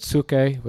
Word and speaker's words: psuche: It's the word psuche with the silent P.